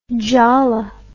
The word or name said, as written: jhala